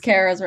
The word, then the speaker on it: Kara: Yes, Kara's right.